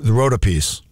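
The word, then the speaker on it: Rhodopes: The next track is simply titled Love Song from the Rhoda piece.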